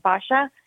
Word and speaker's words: Basia: So technically her name is Barbara and it's pronounced Basha.